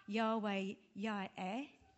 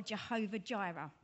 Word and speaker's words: Yahweh Yireh: And this is um, pronounced Yahweh Yireh in the Hebrew or Jehovah Jireh, which comes from the Latin.